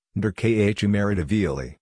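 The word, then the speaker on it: Nodar Kumaritashvili: The featured article for Friday, February 4, 2022 is Nadar Kumaritashvili. Nadar Kumaritashvili, Georgian, pronounced Nderkhumaritavili,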